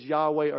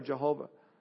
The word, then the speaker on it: Yahweh: an unspeakable name that since about the 12th century has been pronounced as Yahweh or Jehovah.